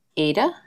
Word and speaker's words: Ada: It's A-D-A. Do you know how it's pronounced? – Ada? – Ada.